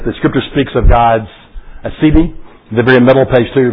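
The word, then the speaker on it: aseity: The word Asidi, pronounced Asidi, comes from the Latin prefix a, which means from, and the root c, which means oneself.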